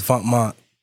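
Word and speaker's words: Vetements: This is when Vontmont Venements, for those that don't know, but it's Vontmont pronounced clothing in French.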